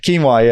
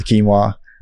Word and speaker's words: quinoa: I should have said it your way, so you followed quinoa. Yeah, like Joaquin, but the other way around, quinoa.